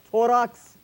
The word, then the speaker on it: Torrox: I have standing with me two handsome men all the way from Torrox, Spain.